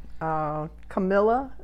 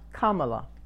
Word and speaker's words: Kamala: Camilla, is that how you pronounce her name? – Harris, I don't know what we're talking about. – The vice president nominee. – Kamala.